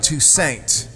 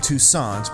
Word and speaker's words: Toussaint: The song is Get Out of My Life, Woman, and it was written by New Orleans great Alan Toussaint. I used to call him Toussaint, but then I heard an interview where he said the correct pronunciation of his name is Toussaint, so I've changed my ways, baby.